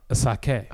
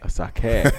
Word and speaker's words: Asake: I said it wrong. Asake. – Asake.